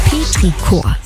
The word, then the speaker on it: Petrichor: Petrichor.